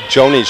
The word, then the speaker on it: Jonnie: I called Ms. Bass, Johnny Bass. It's actually Joni, she corrected me, even though it's spelled J-O-N-N-I-E. – Yeah, J-O-N-N-I-E.